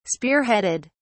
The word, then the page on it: spearheaded: British English